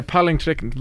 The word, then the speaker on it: Palingtrekken: Yeah, Palingtrekken. Very good, mate. That's how it's pronounced.